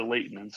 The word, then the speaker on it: Layton: And we found in his journal that he actually bemoaned someone pronouncing the T, because he was from England and a part of England where they didn't pronounce the T's, and it's Layton.